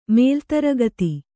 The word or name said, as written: ಮೇಲ್ತರಗತಿ